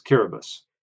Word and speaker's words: Kiribati: So the T-I is pronounced as a S in the language. So, therefore, the correct pronunciation is Kiribati.